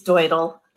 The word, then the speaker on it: Deutl: And I don't want to call you out right away, but you introduced us so nicely as Amy and David, and our last name is Deutl, which nobody normally can pronounce. So I'll just say it's Deutl.